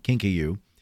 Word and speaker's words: kinkajou: Um, listen, if you know, if you're in Florida and you go to a, a petting zoo and they have a kinkajou, and I think that's pronounced pronunciated. So it's K I N K A J O U, kinkajou.